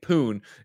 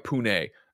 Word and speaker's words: Pune: Redeem Shawshank says the pronunciation, as I was saying, poon, is more like pune.